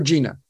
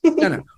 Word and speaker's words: Jena: Is it Jenna or Gina? – It's Jenna. Good job. – Jenna.